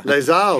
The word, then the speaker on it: Les Halles: H-A-L-L-E-S. – You sure? Les Halles. That's yeah. – Mark. – Yeah, or maybe Paris. Like Les Halles. – There you go. – Les Halles of fame.